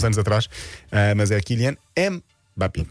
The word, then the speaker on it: Mbappé: Há quem diga Mbappé, Mbappé. Vamos ouvir como se diz na realidade. – Kylian Mbappé. Kylian Mbappé. You do want to pronounce the M here and insist on it.